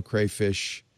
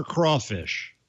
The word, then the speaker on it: crawfish: Crayfish or crawfish? – Ant? – Where I'm from is crawfish. – Okay, I thought so. – Am I saying it wrong? – No, you're not saying it wrong. – I always said crayfish as a kid, but it's spelled crawfish. I thought I was being like all cool in Louisiana style. Crawfish. – Negative. – Negatory. – Negative, that's a negative, sir. It's a crawfish.